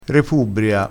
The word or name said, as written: repubbria